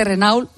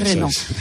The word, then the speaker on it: Renault: Hemos hablado de coches eléctricos con José María Recasens, que es director de estrategia de Renault, porque él lo ha pronunciado así en Albacete Renault de toda la vida, pero es Renault.